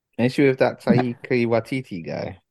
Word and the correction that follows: Taika Waititi: No, you just not pronounced it right at all. – Ain't that what my man's name is? – You said Taika Waititi.